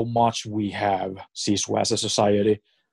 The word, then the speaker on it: sisu: sisu.